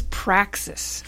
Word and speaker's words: praxis: What did you mean by sacrificial practice? I even said it wrong. Praxis.